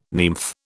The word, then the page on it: nymph: British English